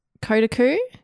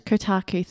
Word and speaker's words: Kotaku: Kotaku. Kotaku.